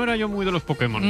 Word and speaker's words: Pokémon: Para permitir que Putemón. – El poder que hay en Pokémon. – Putemón. – Hazte con solo. – Pues hazte con todos, Pudemón.